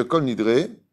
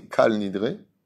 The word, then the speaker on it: Kol Nidre: D'abord, on ne dit pas Kol Nidre, on dit Kal Nidre. Il y a une marloquette au niveau de la prononciation, mais c'est comme ça qu'on dit.